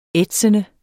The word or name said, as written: ætsende